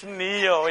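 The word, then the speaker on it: Ne-Yo: Neo. Neo, is that right? How do I say it? It's Neo, yes, Neo. Neo.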